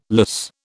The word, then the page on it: lyse: British English